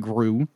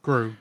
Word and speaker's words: Gru: I'm saying that right, Gru. – Gru, yeah.